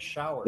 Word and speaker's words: Schauer: That is correct, Schauer.